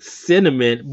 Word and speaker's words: CNMN: Yes, and then he has his sidekick Cinnamon, but it's spelled C-N-M-N and everything.